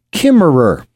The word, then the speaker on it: Kimmerer: Her name is Robin Wall Kimmerer, K-I-M-M-E-R-E-R.